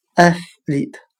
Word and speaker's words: athlete: 今天所学的单词 athlete，运动员，我们就可以通过它的发音联想到汉语的爱使利他。